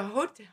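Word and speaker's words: jota: It's a hota, hota is a J, which is so confusing to me, is how you say J in Spanish.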